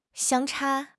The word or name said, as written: 相差